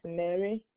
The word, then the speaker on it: Mary: First name is Mary. – How do I spell that? – M-A-R-Y. M-A-R-Y.